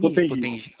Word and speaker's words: Potengi: O bairro é Potengi, é isso? Potengi? – Isso, Potengi. – Potengi. Ok. Porque aqui no meio não aparece acento, não aparece nada, mas a gente pronuncia errado mesmo.